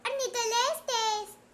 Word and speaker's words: Ornitholestes: Se pronuncia Ornitholestes.